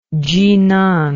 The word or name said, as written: chinan